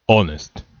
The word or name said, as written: honest